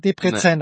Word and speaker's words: Debrecen: Ich weiß gar nicht, Oliver, spricht man es Debrecen nein, oder Debrecen nein.